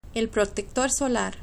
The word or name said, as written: el proctector solar